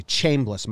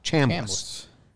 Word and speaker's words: Chambliss: I used to say Chambliss. My dad said Cham, like ham. Chambliss. Mike Held.